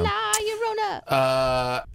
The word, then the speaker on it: La Llorona: Yeah, you said it right. – Yeah, well, it's two L's, so that's a Y sound. – La Llorona.